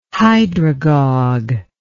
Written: hydragogue

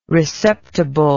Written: receptible

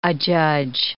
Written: adjudge

